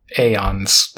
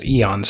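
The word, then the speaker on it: aeon: So the primary and largest catalog divisions of time are periods called eons or aeons, I guess. I'm going to pronounce it eons because it's an E.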